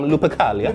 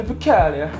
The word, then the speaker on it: Lupercalia: I'll try it again, um, Lupercalia. – Lupercalia.